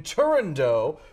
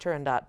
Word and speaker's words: Turandot: Well, it sort of depends on who you ask. So our maestro is insisting upon us singing Turandot. However, a lot of people also say Turandot. I personally actually am of the school that it should be pronounced Turandot, as it is based on a Persian fairy tale.